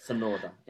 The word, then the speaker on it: Tsunoda: Tsunoda. Yeah. – Yep. Yuki Tsunoda. So Danny Kvyat's out, replaced by Yuki Tsunoda, a Formula Japanese F4 champion and a Red Bull Junior Academy member as well.